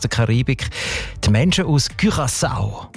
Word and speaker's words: Curaçao: Curaçao, das Land, habe ich gelernt, spricht man eben mit «ü» aus, wegen, weil Holländisch dort auch eine wichtige Sprache ist. Und im Holländischen ist das «ü» offenbar häufig als «ü», Curaçao.